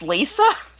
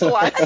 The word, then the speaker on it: Liza: Well, you know, like Liza, I think we all say Liza, but didn't George actually say that it's pronounced Lisa? – George can say that, but it's not. It's Liza.